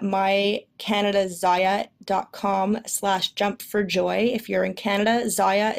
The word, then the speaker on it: Zyia: And again, Zaya is Z-Y-I-A, rhymes with papaya.